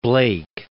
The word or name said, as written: Blake